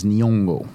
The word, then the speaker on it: Nyong'o: Lupita Nyong'o. – Did I say that right? – Yeah, I think Nyong'o. You did.